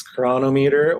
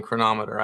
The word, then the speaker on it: Cronometer: How do you say the name of our company? Everyone says it different. – I say it two different ways. I'm not even consistent. It's Cronometer or Cronometer. But those are the only two acceptable pronunciations.